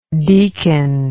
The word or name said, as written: deakin